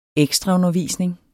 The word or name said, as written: ekstraundervisning